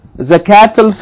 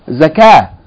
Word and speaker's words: Zakah: Zakatul Fitr. Now, but if you stop, you pronounce the word by itself, Zakah. It's not Zakat. Not in Arabic anyway.